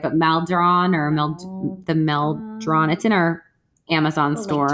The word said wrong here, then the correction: Maldon: I think it's Maldon, M-A-L-D-O-N.